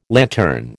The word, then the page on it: lantern: American English